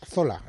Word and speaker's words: Zola: Dejadme que aclare para los oyentes que Emil Zola, como dice el señor poeta, es Zola, es el Zola de toda la vida, el yo acuso.